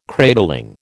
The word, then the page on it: cradling: American English